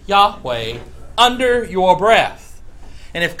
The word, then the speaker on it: Yahweh: And it, if you then sound it out, it's Yah, with the H at the end, Yahweh, or Yahweh, depending upon your preference of pronunciation. Usually pronounced Yahweh. Again, it's from right to left, Yahweh.